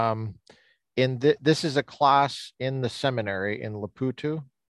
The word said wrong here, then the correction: Maputo: How do you say the word of the name of the city? – Maputo. – Maputo. Sorry. Maputo.